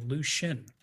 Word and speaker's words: Lucien: I have a silent SH in the middle because you pronounce my name Lucian. So, you know, and there's no SH in there.